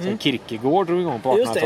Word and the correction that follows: Kierkegaard: Menar du Kierkegaard? Jag är väldigt noga med uttal.